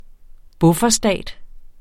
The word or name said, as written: bufferstat